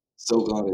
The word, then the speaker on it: Sogon: And it's, you know, the way I spell it is, it sort of mimics the way how Koreans say my name. So okay. Yeah, but I come to realize that it's, you know, people started, you know, pronouncing it differently, and there's just really no right way to do it. But yeah, Sogon is, yeah.